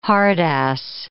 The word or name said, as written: hard-ass